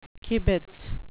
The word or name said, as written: kibitz